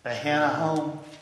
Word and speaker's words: Ahinoam: And Abishai said to David, David also took, and I'm not even going to try and pronounce her name, Ahinoam. – There'll be a short I there, according to the markings in my Bible, so Ahinoam.